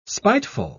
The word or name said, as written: spiteful